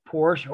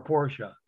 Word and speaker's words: Porsche: Because everybody wants to have a Porsche or a Porsche, depending on how you want to pronounce it. One syllable or two.